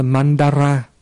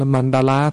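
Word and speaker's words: maṇḍala: Nhưng mà chữ Ấn Độ đó đọc là mandara. Nhưng mà cái chữ ra này khó đọc quá cho nên người ta biên ra là cũng là mandala thôi.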